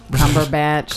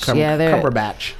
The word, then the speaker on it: Cumberbatch: Cumberbatch. – Cumberbatch.